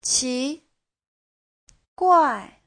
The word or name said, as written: qi2guai4